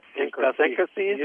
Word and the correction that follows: idiosyncrasy: or whatever. – How do you say that? – Synchrosyncrasies. – That's a new word. Webster. We're calling Webster's idiosyncrasy. – Idiosyncrasy.